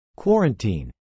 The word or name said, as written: quarantine